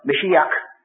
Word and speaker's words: Mashiach: Mashiach, of course, was the true pronunciation of the word Messiah. We have not got the gutturals they have.